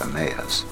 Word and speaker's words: Emmaus: The journey to Emmaus, commonly pronounced Emmaus, was already in progress.